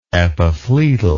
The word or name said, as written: epiphloedal